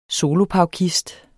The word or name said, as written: solopaukist